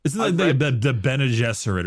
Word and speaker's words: Bene Gesserit: And there's things like psykers in the form of I thought it was pronounced Bean Gesserit, which is not how you pronounce that. – The Bene Gesserit? – Yeah. Yeah, it's not Bean Gesserit.